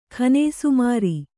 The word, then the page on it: ಖಾನೇಸುಮಾರಿ: kannada